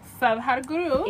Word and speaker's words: Sadhguru: Sadhguru.